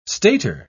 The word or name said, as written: stater